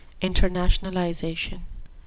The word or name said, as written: internationalization